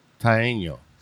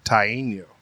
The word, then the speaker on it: Taino: What is it, a Tyano? – Tyano. – Taino. Okay. I'll go with that because it's actually T-A-I-N-O, so he used every letter in there and said A-I-N-U-O-O.